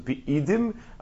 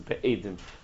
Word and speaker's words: be'idim: Probably the proper way to pronounce this word is be'idim, but the yeshivish way to pronounce it is be'edim, as if it's witnesses.